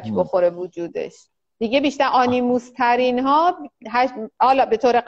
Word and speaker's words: آنیموس: آنیموس تلفظ درستش آنیموس